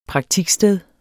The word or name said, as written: praktiksted